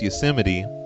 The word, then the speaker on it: Yosemite: And I'm like, Yosemite Road, I think is what it's called. But now I know, thanks to her, that it's pronounced Yosemite.